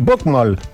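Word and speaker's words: bokmål: Zacznij pan z łaski swojej bukmol wymawiać poprawnie, czyli bukmol. Bo mnie córka głowę suszy, że w mojej ulubionej audycji taki błąd się cyklicznie powtarza.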